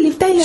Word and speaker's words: Liv Tyler: ¿Se dice así, Liv Tyler? – Sí, sí.